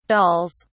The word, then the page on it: dolls: British English